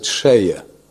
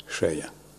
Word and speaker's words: Selye: The guy who invented the word stress, this was some Hungarian, Austrian, he's written Selye, but I understand Hungarians pronounce it Sheye. Sheye.